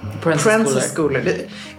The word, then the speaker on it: Proenza Schouler: Pro and a schooler. – Prensa Schooler. Uttalas P-R-O-E-N-Z-A S-C-H-O-U-L-E-R.